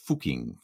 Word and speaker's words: Fucking: Although I will say I'm also pronouncing it a little bit oddly. It's actually should be pronounced Fucking, I think. Fucking. We have to get the Austrian in there.